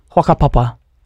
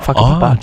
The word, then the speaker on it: whakapapa: Oh, we don't pronounce the W, so we'd say like whakapapa, like not whakapapa.